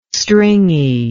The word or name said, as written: stringy